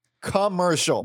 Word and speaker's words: Kamurshol: It's spelled K-A-M-U-R-S-H-O-L. Commercial. – That's a stretch, even phonetically.